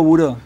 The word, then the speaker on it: Bureau: Polvo Buró, sí. Y que está ah, Buró. Buró, sí, sí, sí. – Polvo Buró. – oficina, sí. se escribe Bureau. Eh, bureau, sí, sí. Lo que buscar en internet es Bureau.